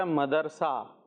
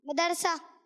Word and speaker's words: مدرسہ: ہاں پہلے تھا مدرسہ اور اب ہے مدرسہ، تو درست کیا ہے؟